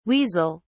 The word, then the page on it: weasel(s): British English